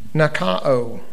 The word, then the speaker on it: nikao: That word "overcomes" in the Greek is, and I may not pronounce this right, nikao, N-I-K-A-H-O.